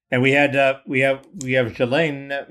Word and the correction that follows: Ghislaine: Maxwell. Oh, Ghislaine. Uh, Ghislaine, yeah, so she she Sorry.